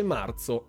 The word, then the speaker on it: marzo: Sono stato corretto all'interno del video perché non si dice marzo ma si dice marzo, ok? Con un z forte. Eh, quindi stiamo attenti a quello che si dice perché c'è in giro la Bocconi.